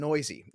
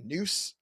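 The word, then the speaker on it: Neuse: But the other guy is Sheldon Noisy. Now, I had to get corrected because earlier I said Noose, I think, earlier in the week. And it's Noisy.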